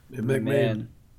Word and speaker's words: McMahon: Wird der, wird der Vince McMahon ausgesprochen oder Vince McMahon? – McMahon. – Man. – So wie, so wie Rasenman, oder?